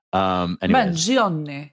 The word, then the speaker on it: Mangione: Obviously, Luigi Mangione. I still know I'm pronouncing that name wrong. – Mangione.